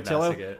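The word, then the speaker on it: Ötillö: It's O-T-I-L-L-O. Otillo. – Yeah, we're not even going to bother. – Americans would say Otillo. I think the Swedish pronunciation, and I'm constantly butchering this, but I think it's something like Otillo.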